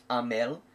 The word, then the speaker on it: Hamel: there's quite a few Hamels which are pronounced Amel.